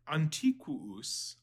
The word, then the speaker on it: antiquus: Antiquus aum. This is old or ancient, obviously. We have antique, antiquated, other derivative forms from this. Antiquus. Notice that there are two U's, so they're both pronounced. So antiquus.